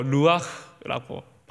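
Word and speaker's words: 루아흐라고 (רוח): 근데 이 바람이 히브리어로 루아흐라고 발음을 합니다.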